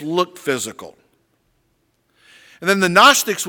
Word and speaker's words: Gnostics: Gnostics, spelled with a G. The G is silent.